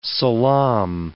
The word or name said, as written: salaam